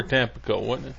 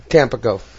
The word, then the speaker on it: Tampico: Is Tampico or Tampico? – Tampico. – Tampico. I've been saying it wrong all this time.